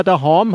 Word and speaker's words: daheim: Und ähm, wir kommen auch jetzt gleich mal zu diesem Thema daheim. Habe ich das richtig ausgesprochen? Daheim? Ein bisschen fränkisch noch. Daheim.